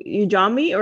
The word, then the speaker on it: Ujamaa: He formed Umanji. Am I saying that right? Ujamaa, or I think I'm saying it wrong, Ujamaa. But Ujamaa, sorry.